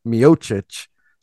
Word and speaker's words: Miocic: Think of like Stipe Miocic is CIC. – I can't do that. – Yeah, his last name is CIC, just like Ethan Posick's name is CIC. and his name is pronounced Miocic. So it makes sense that Ethan Pocic's name is also pronounced Pocic.